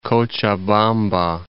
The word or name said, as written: Cochabamba